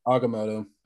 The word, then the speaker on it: Agamotto: Agamotto. – I forget how it's pronounced. Agamotto. – It's like avocado, affogato, Agamotto.